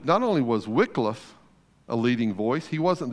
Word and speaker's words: Wycliffe: And so the Wycliffe translation, and by the way, it is pronounced Wycliffe, that Y gets an I sound, becomes the first English translation into a more contemporary English.